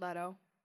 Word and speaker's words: Leto: Jared Leto wanted to wear, that's how I say his name, right? You guys? – Yeah.